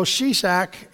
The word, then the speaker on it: Shishak: So Shishak is Shishak. I listened to the audio so I would know how to pronounce the name.